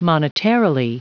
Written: monetarily

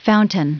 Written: fountain

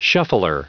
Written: shuffler

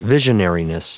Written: visionariness

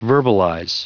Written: verbalize

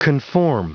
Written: conform

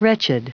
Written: wretched

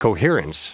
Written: coherence

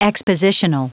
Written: expositional

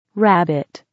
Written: rabbit

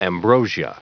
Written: ambrosia

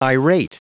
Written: irate

